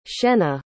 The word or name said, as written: shenna